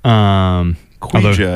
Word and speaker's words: Ouija: Ouija, I believe it's pronounced.